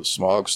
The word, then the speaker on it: Smaug: All the Smaug stuff was cool, except I didn't like how they pronounce his name, which sounds weird. Smaug. – Yeah, I think that's how it's supposed to be pronounced, though.